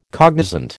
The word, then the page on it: cognizant: American English